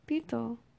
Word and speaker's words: pito: How do you say pito? Say it. – Pito. – There you go.